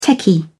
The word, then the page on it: techie: British English